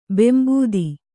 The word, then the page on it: ಬೆಂಬೂದಿ: bembūdi